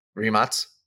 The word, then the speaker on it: Rimac: When you're driving like a Rimac, is that how you say it? – Rimac, yeah. – Rimac.